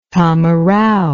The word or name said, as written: tamarao